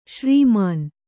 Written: ಶ್ರೀಮಾನ್

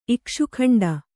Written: ಇಕ್ಷುಕಾಂಡ

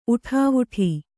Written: ಊಟವಿಡು

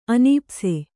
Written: ಅನೀಪ್ಸೆ

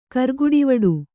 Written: ಕರ್ಗುಡಿವಡು

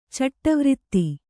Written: ಚಟ್ಟವ್ರಿತ್ತಿ

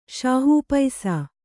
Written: ಶಾಹೂಪೈಸಾ

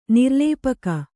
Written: ನಿರ್ಲೇಪಗೈ

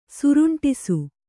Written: ಸುರುಂಟಿಸು

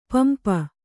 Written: ಪಂಪ

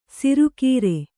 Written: ಶಾರ್ಕರ

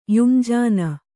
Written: ಯುಂಜಾನ

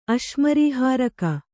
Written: ಅಶ್ಮರೀಹಾರಕ

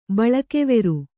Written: ಬಳಕೆವೆರು

